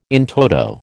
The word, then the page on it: in toto: American English